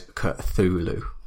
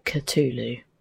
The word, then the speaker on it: Cthulhu: So Wikipedia has it printed as ka hyphen t h o o hyphen lu. So Wikipedia says Cthulhu. – But there are letters that Lovecraft wrote which say Cthulhu.